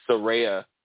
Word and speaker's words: Saraya: And my fear is, you know, honestly, I don't want Saraya. Did I say it right? – Yeah.